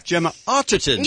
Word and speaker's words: Gemma Arterton: Gemma Arterton.